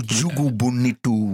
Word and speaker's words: O Jogo Bonito: Je schrijft op O Jogo Bonito, maar zo spreek je het dus totaal niet uit. – O Jogo Bonito.